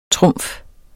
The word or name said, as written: trumf